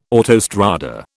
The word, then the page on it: autostrada: British English